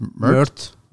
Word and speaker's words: Mert: M-E-R-T. – It pronounced Mert? – Mert, okay.